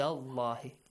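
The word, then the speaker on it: الله: The word Allah, the letter lam in the word Allah is pronounced heavily because of the...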